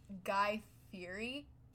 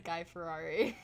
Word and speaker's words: Fieri: Have you ever heard of Guy Fieri? Did I say it wrong? – I'm gonna be honest, I thought it was Guy Ferrari. So I don't know who's in the wrong. – Well, it's spelled F-I-E-R-I. Ferrari. I don't know where you got the Rari.